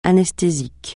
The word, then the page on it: anesthésique: francais